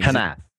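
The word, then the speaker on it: Hnath: Hanath.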